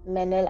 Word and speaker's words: Manal: Also mein Instagram ist Manal, M-E-N-E-L, unterstrich 44, unterstrich. – Warum eigentlich nicht Manal, unterstrich 44? – Also mein Name wird Manal geschrieben, mhm, ja, aber es wird Manal ausgesprochen.